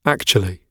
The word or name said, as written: actually